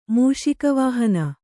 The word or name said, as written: ಮೂಷಿಕವಾಹನ